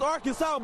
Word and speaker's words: Arkansas: And that's how you pronounce it, ain't Arkin. It's Arkansas, baby.